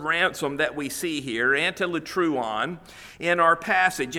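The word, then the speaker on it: antilutron: Now, the word that is used here is antilutruon. Antilutruon, let me spell that for you. A-N-T-I-L-U-T-R-O-N. A-N-T-I-L-U-T-R-O-N.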